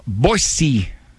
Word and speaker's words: Boise: All right, Kurt from Boise, Idaho.